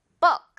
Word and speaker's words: book: 책 역시 북 아니고 book 이렇게 좀 가볍게 처리하면서 자 듣고 갑니다.